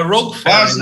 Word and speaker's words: Rogue Ferry: De onde vem esse nome, Rock Ferry? Se é pronto, que eu estou pronunciando corretamente. – Tá, quase lá, quase um pouquinho. É Rogue Ferry, na verdade. Rogue. É como se fosse mercenário, né? – Ah, ok, tem razão.